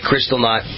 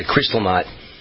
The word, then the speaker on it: Kristallnacht: And yes, some of the things I see, if you heard my opening segment today, remind me of that terrible night, Kristallnacht. I got it right.